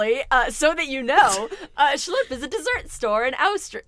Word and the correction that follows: Oswestry: Is that how you say it? Austria. – It's Oswestry. The Are place where kidding? – I live. – That's how you spell that? Oswestry? – O-S-W, Oz, Westry.